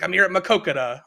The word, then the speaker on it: Maquoketa: He came back this weekend, come back and wins a race at Maquoketa. I'm not even sure how to say that. Do you know that? Do you know how to say it's Maquoketa? – Where the Nippy 50 Maquoketa.